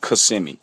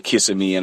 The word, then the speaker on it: Kissimmee: Yeah, it's pronounced Kissimmee. But is that what it is? – Yeah. – Okay. – Yeah, it's Kissimmee. A lot of people say Kissimmee and all that, but you gotta be from here.